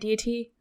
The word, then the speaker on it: deity: Or did I say that right? Deity? – Deity.